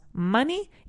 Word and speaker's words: money: Igual que decimos money y no money. Igual que decimos honey y no honey. Right? So it's monkey, money, month, and honey. Aunque sea una O escrito, pues nos da igual. La pronunciamos como una A.